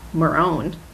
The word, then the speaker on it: Moran: take out Bugs Marone and the Northside Gang. – Moran. – Why did I say it like that? – You were feeling Bugs Moran.